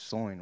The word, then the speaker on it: Soin: It's Soyan, right? – Yeah. See? Rhymes with coin, Soyan.